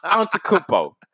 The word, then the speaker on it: Antetokounmpo: Stop it. I said it right this time. Antetokounmpo.